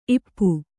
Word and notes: ಇಪ್ಪೆ: ippe; kannada

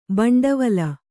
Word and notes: ಬಂಡವಲ: baṇḍavala; Kannada

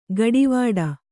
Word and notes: ಗಡಿವಾಡ: gaḍivāḍa; Kannada

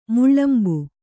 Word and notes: ಮೇಳಂಬ: kannada

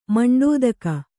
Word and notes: ಮಂಡೆದೂಗು: maṇḍedūgu

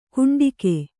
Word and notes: ಕುಂಡಿಗೆ: Kannada

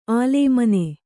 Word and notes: ಅಲೇಮಾನಿ: kannada

